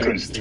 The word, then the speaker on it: Künzli: And correct my pronunciation. How do you say that? – Künzli. – Okay. Künzli. Totally messed that up.